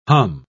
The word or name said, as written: hum